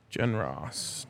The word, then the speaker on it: Genross: Yesterday, we got a crystal ball for Gregory Genros. – Jen Ross. – Jen Ross? – I'll let you let it. I'll let that go. Seems like the most obvious pronunciation.